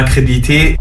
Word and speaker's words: crédibilité: Financièrement parlant, je n'avais aussi aucun intérêt, j'ai perdu des partenariats, j'ai perdu ma crédibilité. – Ta crédibilité? – Ouais. – Non, alors tu as oublié une syllabe, tu as oublié le lit. – Crédibilité, c'est comme débilité. Tu retiens, c'est comme débilité.